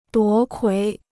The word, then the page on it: 夺魁: duó kuí